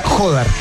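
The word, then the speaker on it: Jódar: Hoy día vi que Feliciano López dijo que era Jodar, con acento en la voz. – Ya, perfecto. Jodar.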